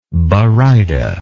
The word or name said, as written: baryta